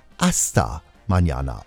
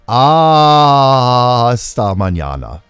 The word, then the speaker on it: Hasta Mañana: Also der Titel wird geschrieben Hasta Mañana mit einem H, aber nicht so ausgesprochen, sondern ohne H oder im Englischen H. Sprich Hasta Mañana oder noch deutlicher, pass auf jetzt, Achtung, Hasta Mañana.